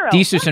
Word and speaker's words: Desus: Just say it like Jesus, Desus. – Desus.